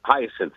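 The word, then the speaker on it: Hiaasen: How do you pronounce the guy's name? Is it Carl Hassan? – Hyacin. – Thank you.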